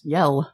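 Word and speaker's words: Yelle: Yell.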